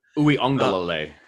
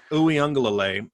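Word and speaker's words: Uiagalelei: Uyunglele, right? Is that, am I finally saying it right? – Uyunglele. – Uyunglele. – There you go.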